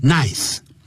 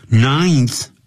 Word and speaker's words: ninth: 么什么重大的成就啊。就是他这种人呢，我刚才我在用英语在讲的时候，就是 he was regarded as a good for nothing, 是一个这个窝囊废。Good, G-O-O-D, 加个 hyphen, 短线符号，再加个 for, 一个介词，再放一个短线符号，再放一个 nothing。A good for nothing, 就是一个窝囊废，是一个废物一个。After Arthur's ninth, 因为有一个N-I-N, 后头有一个 N, 所以有 be ninth, 不要念成 nice, ninth birthday.